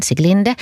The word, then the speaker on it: Siglinde: Och så har vi då Sikli, eller Siglinde, hur den uttalas, Siglinde.